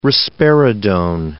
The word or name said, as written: risperidone